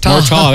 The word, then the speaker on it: Murtaugh: Murtaugh. That's how you pronounce it in America.